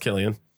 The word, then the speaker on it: Cillian: Because it's spelled with a C, right? Yeah, it's C-I-L-L-I-A-N. – Yeah, but that was pronounced Killian.